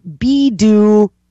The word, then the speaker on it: Baidu: Baidu, as I call them, and I'm going to correct, it's Bidu.